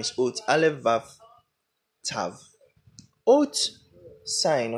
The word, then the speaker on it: Ot: The word for signs there is Ot, spelled Aleph Vav Tav.